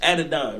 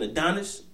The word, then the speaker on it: Adonai: It's Adonai, right? I keep hearing niggas saying Adonis. Adonai, I'm like, you motherfuckers can't read.